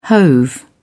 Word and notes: hove: British English

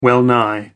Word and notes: well nigh: American English

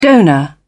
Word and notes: donor: British English

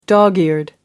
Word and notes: dog-eared: American English

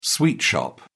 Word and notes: sweet shop: British English

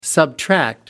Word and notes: subtract: American English

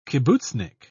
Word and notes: kibbutznik: British English

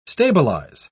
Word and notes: stabilise: British English

